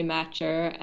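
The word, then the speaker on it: Matchar: Yeah, well, my Twitter is just my name, Emily Matcher.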